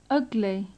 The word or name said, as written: ugly